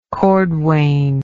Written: cordwain

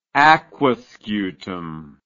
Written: Aquascutum

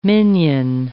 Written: minyan

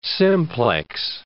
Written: simplex

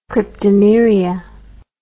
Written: cryptomeria

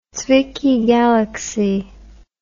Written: Zwicky galaxy

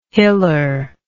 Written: hiller

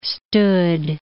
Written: stood

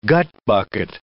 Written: gutbucket

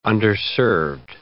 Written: underserved

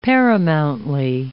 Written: paramountly

